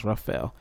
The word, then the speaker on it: Rafael: All right, and the team that had the best draft, in my opinion, which I've said that Rafael Stone, all right, his name is spelled exactly like mine, R-A-F-A-E-L, but it's pronounced Rafael. So it's kind of hard for me to not want to call him Rafael.